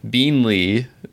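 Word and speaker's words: Beenleigh: that we in the past had a habit of mispronouncing quite a bit. So I'm going to try to pronounce it correctly when I say that I'm going to Beanley Distillery in Australia, and I'm selecting the Transcontinental Rum Line Australia 2013 6-year aged Beanley rum from Australia.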